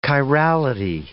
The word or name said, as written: chirality